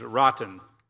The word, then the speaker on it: Rajan: His name is Rotten. It sounds kind of bad, but that's how you say it, is Rotten.